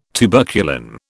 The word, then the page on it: tuberculin: British English